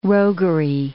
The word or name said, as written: roguery